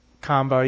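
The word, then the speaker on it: Qanba: Now, I showed him a picture of the Hori type of stick that's being made by Arc System Works, and another one, Quenang, however you pronounce that name. – Kamba. – Kamba, yeah. And that'll come to the United States, don't worry.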